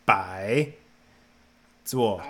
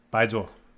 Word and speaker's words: bai zuo: B-A-I, bai, and it's a tone. It's bai, zuo, and zuo is left. – Bai zuo. – That's it. – Oh, it's just B-A-I. – Bai zuo. B-A-I-Z-U-O.